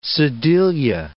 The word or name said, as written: sedilia